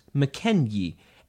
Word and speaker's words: Mackenzie: For English speakers, the best-known word affected by this unintended shift is the name Mackenzie. Mackenzie was originally pronounced McKenyi, and that Y sound was originally represented by a Yogue.